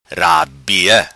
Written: Rabia

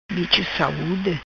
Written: Bicho Saude

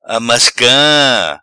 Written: Amasca

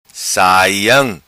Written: Saiam

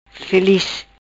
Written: Filiz

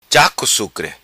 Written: Sucre Cha Co

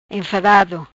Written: Enfadado

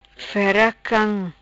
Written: fera cam

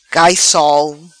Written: Cai Sol

